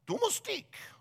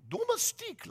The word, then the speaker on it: domestique: So I asked Google how to pronounce it, and this lady voice came on, domestique, domestique, like that.